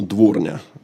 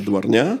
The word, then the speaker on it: дворня: Действительно, дворня, а не дворня.